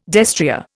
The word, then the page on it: destrier: Indian